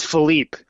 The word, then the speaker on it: Philippe: It's Philippe. So it's like Philip, but it's the French version because my mom is from Belgium.